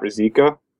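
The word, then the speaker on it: Ruzicka: Mine is my name, so it's my Twitter handle is at Matt Rizika.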